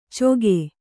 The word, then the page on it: ಚೊಗೆ: kannada